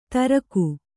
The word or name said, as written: ತರೀಖು